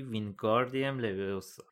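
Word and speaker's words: Wingardium Leviosa: هرمیونی با اوقات تلخی گفت داری اشتباه تلفظ میکنی، باید بگی وینگاردیم لویوسا. این خیلی فرق داره با اینکه کمک کنی به یکی که درست انجام بده.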